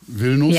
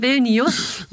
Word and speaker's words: Vilnius: Das erste eigene Kochbuch, Vilnius, ja. Hauptstadt von Litauen, nur mal noch mal kurz erwähnt. – Sehr gut ausgesprochen. – Danke. – Toll. Du, so viele sagen Vilnius.